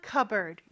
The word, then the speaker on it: cupboard: 자, 근데 발음할 때는 P 발음을 안 하고 cupboard 이렇게 발음한다는 것도 기억해 주세요. 천장 안에 있는 거니까 in the cupboard 이렇게 말을 한 거고요.